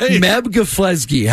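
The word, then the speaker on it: Meb Keflezighi: Meb Geflezgi. How do you say his name again? – No, that's it. You nailed it.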